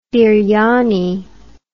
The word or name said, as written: biryani